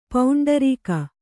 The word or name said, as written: ಪೌಂಡರೀಕ